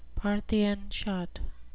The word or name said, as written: parthian shot